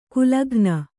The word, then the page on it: ಕುಲಘ್ನ: kulaghna